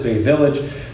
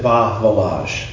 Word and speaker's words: Bay Village: don't pronounce it Bay Village, pronounce it Bah Village.